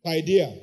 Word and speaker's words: paideia: P-A-I. I'm not a Greek man. D-E-I-A. I think it's just two, three syllables. Paideia, yes. Paideia. P-A-I-D-E-I-A.